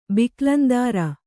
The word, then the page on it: ಬಿಕ್ಲಂದಾರ: biklamdāra